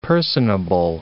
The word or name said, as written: personable